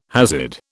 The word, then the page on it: Hasid: British English